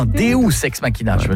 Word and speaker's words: Deus ex machina: Deux ex machina. Non, Deus ex machina. – Ah, parce que deux, j'aurais dit que c'était deux ex que t'appelles machine. – Non, ça se prononce Deus ex machina.